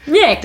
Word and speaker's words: Jeck: so wie beim, Köln, so wie beim Kölner Karneval. – Jeck.